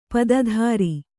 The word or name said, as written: ಪತ್ತೆದಾರ